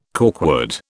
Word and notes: corkwood: British English

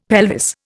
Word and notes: pelvis: Indian

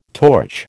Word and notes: torch: American English

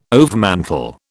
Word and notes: overmantel: British English

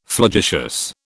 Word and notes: flagitious: British English

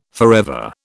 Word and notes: forever: British English

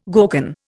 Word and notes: gorgon: Indian

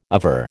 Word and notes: aver: American English

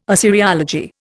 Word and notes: Assyriology: Indian

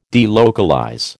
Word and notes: delocalize: American English

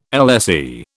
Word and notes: LSE: British English

